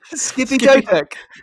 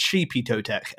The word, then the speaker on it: Xipe Totec: Like it's spelled out Xipe Totec. Well, which to be fair to you, you kind of got it right. It's Sheepy Totec.